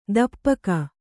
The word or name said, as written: ತುಪಾಕಿ